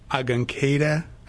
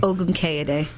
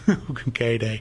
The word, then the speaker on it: Ogunkeda: You mentioned earlier, again, our guest today, Adeola Ogunkeda. I think I butchered it that time. – Ogunkeda. – Ogunkeda.